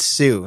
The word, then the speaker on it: Sault: Marie, Michigan, which you would not think it's Sue. Look at how it's spelled. – Where's it at? – Oh. It looks like salt. – Oh, that. – But it's Sue.